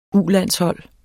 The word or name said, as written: U-landshold